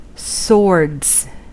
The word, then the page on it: swords: American English; IPA: /ˈsɔɹdz/